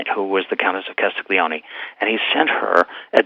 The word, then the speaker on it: Castiglione: Her name was the Countess of Castiglione, and she was the cousin of Camillo Cavour, who was the Prime Minister of Piedmont.